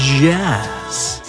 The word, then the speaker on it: Yazz: Det är jazz. Eh, jag försöker uttala y här. Ja, just det. Framgick det? Mm. Y-A-Z-Z.